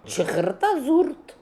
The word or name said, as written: Chrkhrta Zoorrt